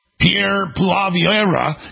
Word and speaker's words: Pierre Poilievre: So, Pierre Poilievre, I got that right.